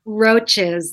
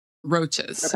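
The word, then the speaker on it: Roches: So the Roaches or Roaches? How do I pronounce the name? – Roaches. Like Roaches. Okay.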